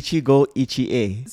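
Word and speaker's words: Ichigo Ichie: Ichigo Iche.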